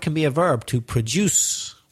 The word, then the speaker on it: produce: So the stress changes. The verb has the stress on the second syllable, produce, and the noun on the first syllable, produce.